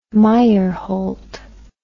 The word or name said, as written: Meyerhold